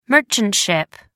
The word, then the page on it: merchant ship: American English